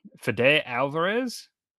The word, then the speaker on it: Fede Alvarez: Fede Alvarez.